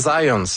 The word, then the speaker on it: Zajonc: c'est Zajonc. – Ouais? OK. – J'ai-tu pas dit Zajonc? – Ben, j'avais dit plus Zajonc, mais... – Ah, ben, je l'ai prononcé un petit peu à l'anglais. J'ai prononcé mon polonais à l'anglais.